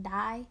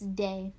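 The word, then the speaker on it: Dai: A lot of people pronounce, like, a lot of people think, like, my name is pronounced, like, die. It's Day. My name is Dejana, but everybody calls me Day for short.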